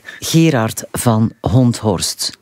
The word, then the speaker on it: Gerard van Honthorst: Het betreft namelijk Gerard van Honthorst. – Helemaal correct uitgesproken.